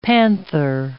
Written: panther

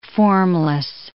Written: formless

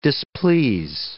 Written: displease

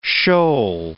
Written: shoal

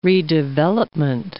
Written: redevelopment

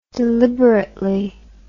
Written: deliberately

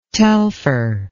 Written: telpher

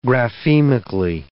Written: graphemically

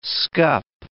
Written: scup

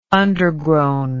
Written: undergrown

